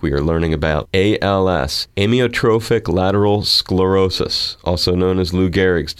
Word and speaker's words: Amyotrophic lateral sclerosis: Amyotrophic lateral sclerosis.